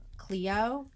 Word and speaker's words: Clio: Cleo, C-L-I-O.